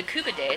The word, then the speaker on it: koobideh: The Kubade, it's spelled K-O-O-B-I-D-E-H. It's like a seasoned ground meat that's formed into like almost like meatballs, but not quite meatballs, and crammed on the kebab and yeah.